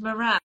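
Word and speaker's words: Marans: How do you say it, Ben? – I I say Maran, don't I? – Maran. – Darling, it's Moran.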